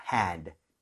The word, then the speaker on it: hand: 입 모양을 좀 많이 벌려주면 더 정말 끝내주는 발음 나올 수 있습니다. Hand.